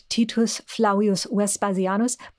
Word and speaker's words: Titus Flavius Vespasianus: yeah i mean he would he would probably have said it titus flavius vespasianus but you can just call him titus flavius vespasianus